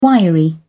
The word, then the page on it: wiry: British English